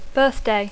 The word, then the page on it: birthday: English